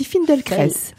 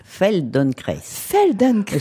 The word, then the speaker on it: Feldenkrais: On dit Feldenkrais. – Feldenkrais. C'est difficile à prononcer, puis il y a beaucoup de prononciations, parce qu'en fait c'est une prononciation russe. Alors si vous prononcez à la française, c'est très bien aussi.